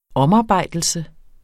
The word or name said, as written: omarbejdelse